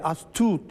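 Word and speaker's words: Astute: Kuzey Buz Denizi'nde İngiliz astut dediğimiz astut diye okunuyor.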